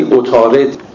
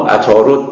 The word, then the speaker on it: عطارد: عطارد که به زبان عربی عطارد تلفظ میشه، ولی ما فارسی زبانان اونو عطارد تلفظ میکنیم، همون سیاره ایست که به فارسی بهش تیر میگیم.